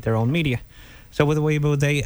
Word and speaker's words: Weibo: Yes, it is Weibo, not Weibo. as many people might just overall generalize it to be due to its foreign-sounding name.